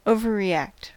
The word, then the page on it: overreact: American English